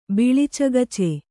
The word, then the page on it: ಬಿಳಿಚಗಚೆ: Kannada